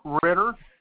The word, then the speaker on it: Ritter: Yes, it's William Ritter, R-I-T-T-E-R.